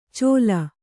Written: ಚೋಲ

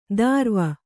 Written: ದಾರ್ವ

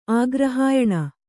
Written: ಆಗ್ರಹಾಯಣ

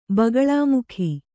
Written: ಬಗಳಾಮುಖಿ

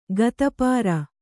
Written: ಗತಪಾರ

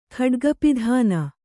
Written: ಖಡ್ಗಪಿಧಾನ

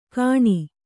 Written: ಗಣಿ

